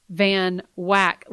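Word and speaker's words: Van Wyck: Now, I noticed that you pronounced it Van Wack. Let me give our listener an idea of how it's spelled. Van Wack is two words. – Yes. – Van, capital V-A-N, mm-hmm. Wack, capital W-Y-C-K. – Yes. – Why is the Wick pronounced Wack?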